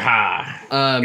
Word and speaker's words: Haugh: I believe it's Ha. – Mr. Ha. – Is it like H-A-U-G-H or something like that? – Yeah, yep. Okay. It's spelled exactly that way.